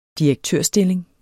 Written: direktørstilling

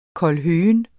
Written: kålhøgen

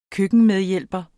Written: køkkenmedhjælper

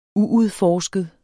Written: uudforsket

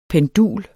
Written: PENDULERNE

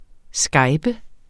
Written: skype

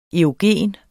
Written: erogen

